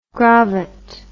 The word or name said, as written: grovet